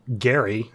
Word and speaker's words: Gerry: The governor there, Elbridge Gerry, I believe is actually how you pronounced his name, and that was in 1812 that he signed the bill that started the whole thing.